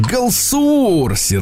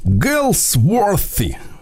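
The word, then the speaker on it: Galsworthy: В 1867-м Джон Галсуорси родился. Угу. А, вообще фамилия, конечно, произносится не так. А Я как? можно попытаюсь. Давайте, давайте. Гэлсворти. Вот так.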